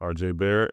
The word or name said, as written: RJ Barrett